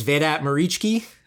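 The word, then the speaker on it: Vedat Muriqi: So their big signing of the summer, I'm not even sure if I'm pronouncing this correctly, is Vedat Muricki. Based on some YouTube videos I watched, that's how the pronunciation goes.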